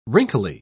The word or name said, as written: wrinkly